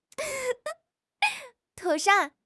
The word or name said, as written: 妥善